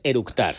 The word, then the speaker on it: eructar: y lo primero que hizo es orutar. – Con orutar es eructar.